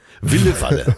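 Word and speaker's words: Ville Valo: Mit Walle Walle am Gesang. – Ja. – Wille Walle.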